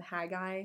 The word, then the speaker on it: Haggai: And also just the theme last year was a passage from Haggai.